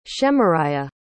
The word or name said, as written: shemariah